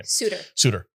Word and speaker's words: Souter: Souter. – It's like shooter.